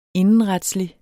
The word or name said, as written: indenretslig